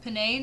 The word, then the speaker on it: penne: and she made it with like penne noodles. How do you say it? – Penne. – Okay, I said it right the first time.